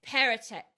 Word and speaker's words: Perrottet: And I cannot pronounce his last name, even though you just literally described it to me before. Dominic. – Perrottet. Yeah. – Dominic Perrottet. I knew that.